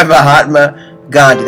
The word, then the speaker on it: Mahatma Gandhi: And that was said by Mahatma Gandhi. That's actually how you pronounce it, because people just usually can't reach that guttural part of their voice.